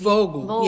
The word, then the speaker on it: Vogel: Vogel? Is it a V? – Vogel. Yeah, Vogel's a V.